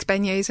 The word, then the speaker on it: beignets: They are, of course, if you've seen the movie The Princess and the Frog, she makes beignets in her cafe at the beginning.